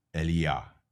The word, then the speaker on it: Eliyah: His real name in Hebrew, I believe, is pronounced Eliah. E-L-I-Y-A-H. The Y-A-H is Yahweh.